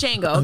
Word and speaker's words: Django: There's a D in there. I just say Django. – It's Django.